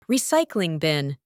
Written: recycling bin

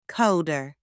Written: coder

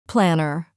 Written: planner

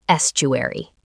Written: estuary

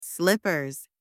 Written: slippers

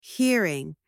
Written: hearing

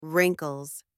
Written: wrinkles

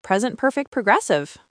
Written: present perfect progressive

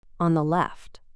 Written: on the left

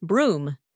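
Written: broom

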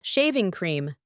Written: shaving cream

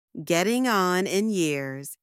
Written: getting on in years

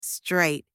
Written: straight

